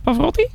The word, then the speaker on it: Pavarotti: Pavarotti, zeg ik dat goed? – Ja, klopt.